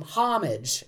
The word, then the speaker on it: homage: Initially, it was pronounced, it comes from the Normans, bringing French to English, and they did say homage. But because it was spelled with an H, and English, in English, most people pronounce the H, the spelling pronunciation took over. So it became homage, as we say it.